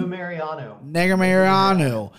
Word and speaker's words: Nurmagomedov: Nurmagomedov versus Kennedy and Zekchukwu.